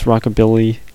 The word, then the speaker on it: rockabilly: Or I can never pronounce rockabilly. It's rockabilly, and I keep saying rockability, which is not right.